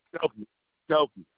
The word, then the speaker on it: Skokie: It's in how you say that? Skookie, Illinois. – It's Skokie. Skokie, Illinois. – You said Skokie? – Yeah, that's how you say it. Skokie, Illinois.